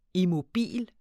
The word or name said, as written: immobil